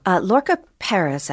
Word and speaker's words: Lorca Perez: Lorca Perez, actually, is how she pronounced it. I know, it doesn't seem to be the way it was written.